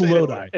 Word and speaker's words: Lodi: Lodi or Lodi? – It's Lodi. – It's Lodi. Lodi.